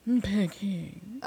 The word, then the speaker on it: Peking: Peking. – Oh, see, there we go. All right, so in Peking. He was in 1964. He was in Peking.